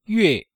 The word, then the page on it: yue: yue4